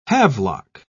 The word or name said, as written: havelock